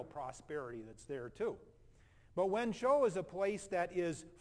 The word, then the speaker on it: Wenzhou: That's how you pronounce that Chinese city name. That's called Wenzhou.